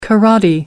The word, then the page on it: karate: American English